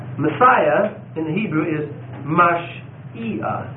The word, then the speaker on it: Mashiach: that's how you pronounce it, Mashiach, and Messiah in English.